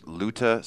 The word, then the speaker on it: Luta: And you're an entrepreneur and you're firm is Luta Security.